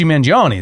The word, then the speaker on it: Mangione: Luigi Mangione is being the best example. See that pronunciation? – Sure. – Did I nail that? – Did great.